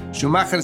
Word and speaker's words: Schumacher: Schumacher se escribe S de sopa, C de casa, H-U-M-A-C-H-E-R.